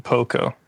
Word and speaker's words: Pokko: Yeah, that's how I came across the work, is Pokko. Or I don't know if you pronounce it Pokko. – Yeah. Yeah, that's how I pronounce it.